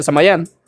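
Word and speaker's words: Samhain: Por si quieren conocer un poco más de la historia de Samaín, que por cierto se pronuncia Samayán,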